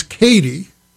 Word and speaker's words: Caity: Here is a woman whose first name is Katie, but it is spelled C-A-I-T-Y. That's Katie. And her second name is G-Y-O-R-G-Y, but it's pronounced George.